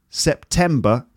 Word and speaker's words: September: Okay, next, September. Second syllable is stressed. It's three syllables and the second syllable.